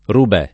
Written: Rubè

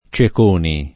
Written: Ceconi

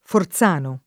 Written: Forzano

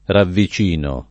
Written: ravvicinare

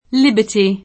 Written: Liberty

